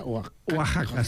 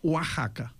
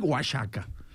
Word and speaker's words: Oaxaca: 1519, doncs 1529, unes monges carmelites de Oaxaca, Oaxaca, no sé com s'ha de pronunciar. – Oaxaca s'ha de pronunciar. – Com? – Oaxaca. – Oaxaca. – I en castellà antic, Oaxaca.